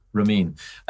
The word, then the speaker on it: Ramin: So you've been at Remote Control Studios with Ramin. Am I saying that right? – Ramin.